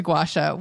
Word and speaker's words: gua sha: So it's two words, gua, G-U-A, sha, S-H-A. But when I hear people say it, it kind of comes out almost like one word, like gua sha.